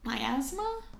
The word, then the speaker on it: miasma: And the medical opinion on the spread of cholera was that it was caused by, like, bad air or miasma? I don't know. M-I-A-S-M-A. – I feel like I've heard it pronounced miasma.